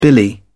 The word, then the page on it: billy: British English